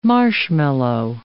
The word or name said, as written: marshmallow